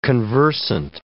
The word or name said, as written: conversant